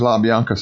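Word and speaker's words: LaBianca: It is La Bianca, so all these years I've been saying it wrong.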